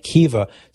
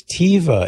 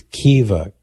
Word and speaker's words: Caoimhe: It is Irish and it's spelled C-A-O-I-M-H-E. It's phonetically pronounced like Kiva.